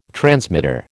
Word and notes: transmitter: American English

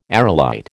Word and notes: aerolite: American English

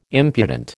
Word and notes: impudent: American English

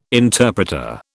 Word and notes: interpreter: British English

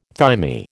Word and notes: thymi: American English

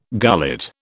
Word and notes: gullet: British English

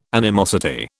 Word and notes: animosity: British English

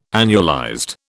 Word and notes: annualized: British English

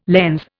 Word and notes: lens: Indian

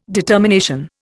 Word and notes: determination: Indian

